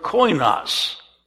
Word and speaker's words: koinos: Could you pronounce that for me? Koinonia, from the word koinos. Koinos, which means common.